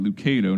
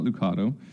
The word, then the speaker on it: Lucado: Lucado, not Lucado.